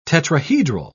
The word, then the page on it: tetrahedral: British English